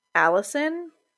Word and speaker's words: allicin: And this is primarily because the plants contain a compound called allicin, which is spelled A-L-L-I-C-E-N. I'm sorry for all the people named Allison out there.